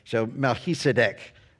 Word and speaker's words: Melchizedek: So if you were to break it down like Mel, M-E-L, and he, like H-E, that's the C-H-I, actually gives you the sound H-E, and then S-I-D, said, and then D-E-C-K, deck, like a deck. So Melchizedek, that's how this name is pronounced.